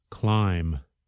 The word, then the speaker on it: climb: They are not pronounced at all, like the letter B at the end of the word climb.